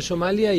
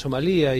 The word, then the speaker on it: Somalia: No, en el mundo es en Somalia y acá le llamamos Somalía.